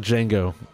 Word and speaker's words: Django: Just like Django, taking back. – Taking it back, and the J is silent. – That's right. Or the D is silent.